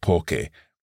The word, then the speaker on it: poche: and therefore we need to add in an H in order to keep that C hard. Likewise with poche, the feminine plural form.